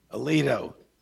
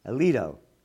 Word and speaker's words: Alito: The fetus has an interest in having a life by Supreme Court Justice Samuel A. Aliuto. Is that the correct pronunciation? – Alito. – Alito. Yep.